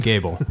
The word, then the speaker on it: Gebel: Yeah, you butchered it. It's Gable.